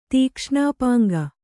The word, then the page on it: ತೀಕ್ಷ್ಣಾಪಾಂಗ: kannada